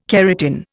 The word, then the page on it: keratin: Indian